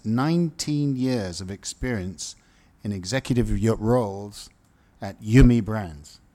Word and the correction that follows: Yum: Yum Brands.